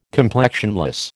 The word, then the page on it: complexionless: American English